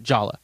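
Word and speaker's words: Jolla: Did some digging on the pronunciation of Yala, and the creators of Yala are actually okay with people calling it Jala. – Oh, really? – Yeah. – Right. – And they, you know, they understand that that's the Western pronunciation of it.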